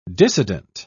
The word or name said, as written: dissident